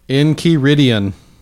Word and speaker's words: Enchiridion: Enchiridion. – Enchiridion. – Enchiridion. – Can you spell it? – Enchiridion. – Enchiridion. – Enchiridion. – E-N? – Yes. – And then C-H-I-R-I. – Mm-hmm. – Kiri.